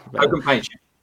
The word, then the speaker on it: Kogan Page: I think that's how you pronounce it. Is that right? – That's right. – Yeah. – Kogan Page.